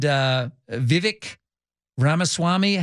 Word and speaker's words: Vivek Ramaswamy: And Nikki Haley and Vivek Ramaswamy, how do you pronounce his name? I still can't get the name. You said it correctly.